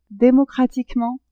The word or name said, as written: democratiquement